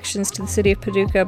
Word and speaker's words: Paducah: Yeah, it's Paducah.